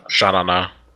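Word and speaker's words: Sha Na Na: Another group by a group called Shanana or Shanene. – Shanana.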